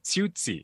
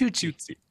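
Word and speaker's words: Qiuqi: The book is called Fiona the Fruit Bat by Dan Riskin, beautifully illustrated by Rachel, is it Kigi? – Tsutsi. – It's spelled with Q's, but the pronunciation is Tsutsi.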